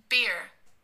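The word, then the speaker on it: bier: and it is pronounced... – beer.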